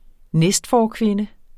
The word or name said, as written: næstforkvinde